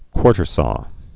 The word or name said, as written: quartersawed